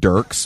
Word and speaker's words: Dierks: It's Dierks Bentley. He pronounces it Dirks, but I'm not gonna do him that service. – Okay, so his name is he spells it Dierks like Dirks with an IE, right?